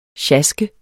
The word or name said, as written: sjask